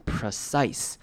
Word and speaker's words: precise: 好， precise 这个字也是要特别注意的，它的 P-R-E 开头的声音呢是念成轻音，所以念成 precise， precise，重音在 size 这个音节上面，所以这边 P-R-E 是可以念成轻音的， precise， precise。